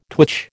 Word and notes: twitch: American English

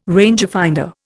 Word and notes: rangefinder: Indian

